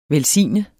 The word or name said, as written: velsigne